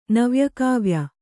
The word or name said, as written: ನವ್ಯಕಾವ್ಯ